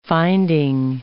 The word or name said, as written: finding